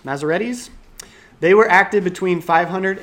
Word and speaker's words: Masoretes: And so what happened with the Masoretes, that's how you pronounce it, I figured it out, is they added those vowel sounds in there.